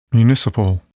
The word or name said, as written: municipal